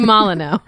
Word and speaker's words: Molyneux: That's how you say your last name? – No. You just say Molyneux.